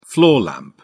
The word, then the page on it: floor lamp: British English